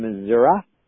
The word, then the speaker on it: Missouri: Thank you, Linda from Washington, Karen from Wisconsin, Ron and Renee from Michigan, Norma from Missouri is how you properly pronounce it.